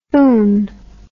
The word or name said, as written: foon